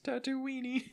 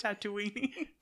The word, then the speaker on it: Tatooine: No, all I can think is Tatooine-y. – Tatooiners. – Tatooine-y. – It is kind of spelled in a way that you might think it's pronounced Tatooine-y. – Tatooine. Okay.